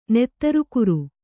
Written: ನೆತ್ತರುಕುರು

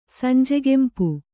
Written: ಸಂಜೆಗೆಂಪು

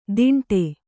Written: ತಿಂಡೆ

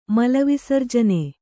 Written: ಮಲವಿಸರ್ಜನೆ

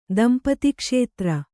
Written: ದಂಪತಿಕ್ಷೇತ್ರ

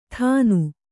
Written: ಥಾನು

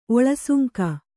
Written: ಒಳಸುಂಕ

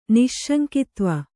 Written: ನಿಸ್ಸಂಗತ್ವ

